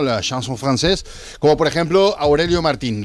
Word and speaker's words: chanson française: Mito de la chanson française, ¿se dice así también? – Chanson, sí, chanson française.